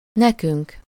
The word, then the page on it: nekünk: /ˈnɛkyŋk/